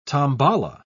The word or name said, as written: tambala